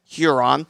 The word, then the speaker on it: Huron: Not sure why I decided to pronounce it Huron, but Huron. Number 4, Keystone, number 5, Van Wert, number 6, Wasion, number 7, Napoleon, and number 8, Kenton.